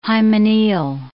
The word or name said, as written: hymeneal